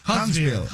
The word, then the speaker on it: Huntsville: ¿Huntsville? ¿Chiri? ¿Sí lo dije bien, o no? – Sí. Huntsville.